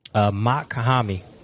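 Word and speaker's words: Mach-Hommy: I can spell it for you. It's MACH. – Uh-huh. – And then you have a what's it called? A hyphen. HOMMY. Mock Hami. You know, like mock, like a fucking jet.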